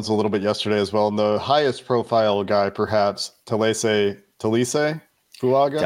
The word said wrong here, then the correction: Taliese: Taliese. – I thought the I wasn't pronounced. Okay, I'm learning. – Islander names, the trick, if there's a trick, or just like how they, is you pronounce every vowel.